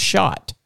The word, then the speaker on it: Schacht: So, but the other car I wanted to talk to you about is this 1908, and it's spelled SCHACHT, but you said it was pronounced shot. – Right, that's correct.